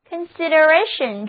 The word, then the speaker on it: consideration: 如果我们在 consider 后面加上 ation 的名词后缀，那么单词 consideration, C-O-N-S-I-D-E-R-A-T-I-O-N, consideration 重音放在倒数第二个音节上，单词就读作 consideration, 这是 consider 的名词，意思是考虑、体贴、关心。